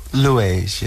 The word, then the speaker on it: Liège: Is that the proper pronunciation? Liège. What did I say? Liège. Well, you know, okay. – Americans, you forgive anything Americans say. – Yeah, we get away with a lot of things. – Yeah, we get away with a lot of stuff. It's close, though. Liège. Yes.